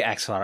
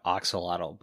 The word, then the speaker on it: axolotl: I say axolotl. I heard some people call it oxolotl, but every time I see it, like the pronunciation written, they say axolotl. So I'm calling it the axolotl.